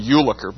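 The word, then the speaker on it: Jülicher: Early in the 20th century, there's a man named Ulliker. By the way, it's spelled J-U-L-I-C-H-E-R.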